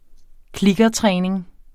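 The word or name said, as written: kliktræning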